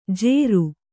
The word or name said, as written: ಜೇರು